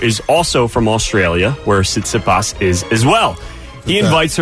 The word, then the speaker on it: Tsitsipas: World number three tennis star Stefanos Tsitsipas, I believe is how you say it. – Yeah, that's right.